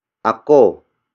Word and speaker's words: ako: Lumakas is spelled as L-U-M-A-K-A-S. It means became strong, or the act of becoming strong has happened. The next word is ako. Ako. Ako. Ako is spelled as A-K-O.